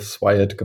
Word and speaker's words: SWIAT: Und daraus haben wir dann den Kunstbegriff SWIAT gemacht. Und auch aufgrund des internationalen Fokus natürlich auch dann die englischsprachige Aussprache gewählt, aber uns begegnet eigentlich jede Aussprache.